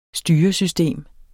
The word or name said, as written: styresystem